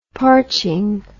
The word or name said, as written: parching